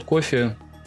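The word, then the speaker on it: Coffey: А Скотт Кофи — это как напиток, только пишется по-другому.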